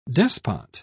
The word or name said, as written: despot